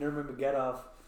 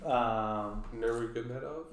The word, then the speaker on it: Nurmagomedov: Well, she's on a tear currently, and also Said Nurmagomedov, which I butchered their name. – Nurmagomedov. – Yes.